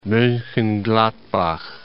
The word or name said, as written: Monchengladbach